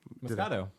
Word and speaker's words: Moscato: Is that how you pronounce it? – Moscato. Yeah, Moscato. – It's like Mosquito, but it's a champagne with an A, Moscato.